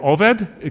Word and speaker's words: Obed: Obed means the word for slave, or Oved. It kind of depends on how you pronounce it.